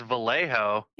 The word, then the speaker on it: Vallejo: The J is silent. Vale. – Valeos. Shit! – I was gonna say it's Vallejo.